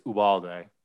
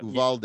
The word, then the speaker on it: Uvalde: There was a massive school shooting in Texas, Uvalde, is that how you pronounce it? – I think it's Uvalde, but Uvalde.